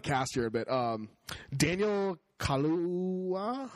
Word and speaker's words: Kaluuya: Oh, yeah, it is Kaluuya.